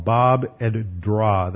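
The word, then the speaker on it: Bab edh-Dhra: Now here's a modern picture of the archaeological discovery of a city near the Arabic settlement called Bab ed-Dra. That's spelled B-A-B, second one lowercase E-D, and then the third is capital D-H-R-A, Bab ed-Dra.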